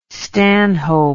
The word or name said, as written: stanhope